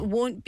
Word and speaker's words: won't: 근데 말하는 게 발음 좀 어려워요, 그거. – It won't. – 왜냐하면요. It won't. 노래에 W 소리가 중요한 소리예요.